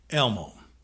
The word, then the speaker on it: LMOE: Elmo. L-M-O-E. – L-M-O-E. – Pronounced Elmo. – Civil Defense Audio Instruction Series, Disc L-M-O-E-A.